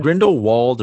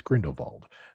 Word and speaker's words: Grindelwald: Is it Grindelwald or Vald? – I think if you use the German pronunciation, it's Grindelwald. The English pronunciation would be Grindelwald.